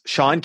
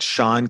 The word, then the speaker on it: Sean: Sean Cannell across social media. That Sean Cannell rhymes with YouTube channel on the last name. First name, S-E-A-N, last name, C-A-N-N-E-L-L across social media.